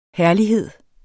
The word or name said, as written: herlighed